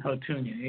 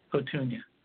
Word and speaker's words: Houttuynia: Sweet wormwood, artemisinin, and hotunia, H-O-U-T-T-U-Y-N-I-N-E, hotunia. I'll spell it again. H-O-U-T-T-U-Y-N-I-A.